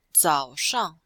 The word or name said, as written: zao3shang4